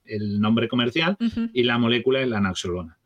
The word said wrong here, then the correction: naloxona: y naloxona, perdón. Naloxona.